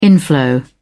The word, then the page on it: inflow: British English